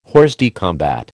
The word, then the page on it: hors de combat: American English